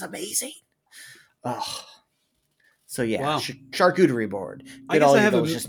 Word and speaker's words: charcuterie: Charcuterie is how you say it in French. Charcuterie.